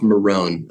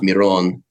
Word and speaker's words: Miron: Pronounced Miron. At least that's how it's pronounced for Americans. It's so I'm a Russian, I'm a Jewish refugee from the Soviet Union, first born in the US. So it would be pronounced Miron. But yeah, in the US I tell people it's Miron. That's how most people know me. It would be phonetically it would be spelled M-E-R-O-N-E.